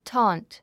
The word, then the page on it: taunt: tɔ́ːnt; タァーント